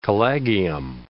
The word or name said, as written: collegium